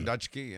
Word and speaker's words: Dutschke: Everett Dushke. – Yes. – Back to the script now. – How would you pronounce D-U-T-S-C-H-K-E? Everett Dushke. – Dushke. – Or Dutchke. Yes. – Or Dutchke. Thank you.